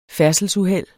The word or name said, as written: færdselsuheld